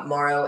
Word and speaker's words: Morrow: So Instagram or TikTok, it's Clare, C-L-A-R-E _IFBBPro. Oh, wait, Clare Morrow. Sorry. C-L-A-R-E. I can't think. I'm so used to looking at it, you know. So my name is not spelled with an I, so it's C-L-A-R-E Morrow, M-O-R-R-O-W _IFBBPro.